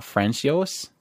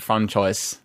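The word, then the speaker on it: François: It's not Francios. – You can go with Francios. – You know they're going to pronounce it Francois.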